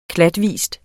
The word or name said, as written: klatvis